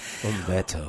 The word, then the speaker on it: Humberto: Well, first of all, I love how she pronounces my name. And with her voice, it sounds really nice. Humberto.